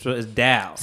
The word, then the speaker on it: Dao: And she said, like, that sound, like, that's it's pronounced with a D sound. So it's Dao.